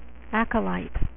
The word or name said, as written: acolytes